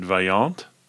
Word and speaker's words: Vaillant: And one of the doubles that you're talking about is how you pronounce it Vaillant. – Mm-hmm. – Okay. – It's French.